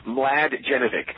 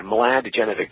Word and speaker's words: Mladjenovic: Mladjenovic. – Mladjenovic. – The first four letters rhyme. Yeah, Mladjenovic. The first four letters rhyme with glad, and then the rest of it is Jenovic, so Mladjenovic.